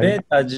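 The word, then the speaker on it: b8ta: 私はベータージャパンの、カントリーマネージャーしてます。 – はい。 – ベータ自体は、まあ、アルファベットの B に数字の 8 で TA でベータって読むんですけども、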